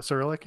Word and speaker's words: Cyrillic: First, like, go with the XOXO, which was always like taunting him, thinking it was Cyrillic and saying, "Did I pronounce that right? Cyrillic?" – Cyrillic, yeah.